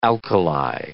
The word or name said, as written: alkali